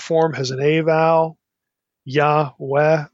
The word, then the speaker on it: Yahweh: So Yahweh instead of Yehweh or Yeh.